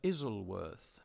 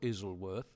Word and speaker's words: Isleworth: Now, I think that's how you pronounce it, because I have great-great-grandparents who were married in a church at what I called Isleworth, Isleworth, but I was told it's not Isleworth, it's Isleworth. So Isleworth or Isleworth, that's where David was born.